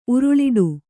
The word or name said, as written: ಉರುಳಿಡು